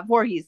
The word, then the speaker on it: Voorhees: New York, I had saying, I'm worse than Jason Voorhees, sweetheart. I'm the HBIC. You can't kill me. I don't know how to say that. How do you say that? Jason Voorhees? – Yeah, Voorhees. Yep.